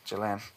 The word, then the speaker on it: Ghislaine: Ghislaine. That's how you pronounce it.